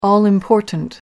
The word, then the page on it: all-important: American English